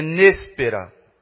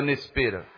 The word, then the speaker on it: néspera: Não sei agora onde é a sílaba tônica, mas é néspera. É néspera. O nome é esse? Néspera ou Nespera? Acho que Nespera não, né? Néspera, né? Lá onde eu morava aqui num quintal, aqui perto da igreja aqui, e tinha algumas frutas lá, algumas árvores frutíferas.